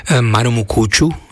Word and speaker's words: Maromokotro: Marumukuču, alebo Maromokotro, ale no, Marumukuču sa to vyslovuje.